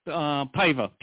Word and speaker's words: Paiva: It's Paiva, P-A-I-V-A.